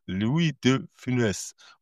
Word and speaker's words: Louis de Funès: Louis de Funès. – Okay. – Genau. Louis, also das ist ein stimmloses S bei Louis, aber bei Funès gibt es quasi ein Funès.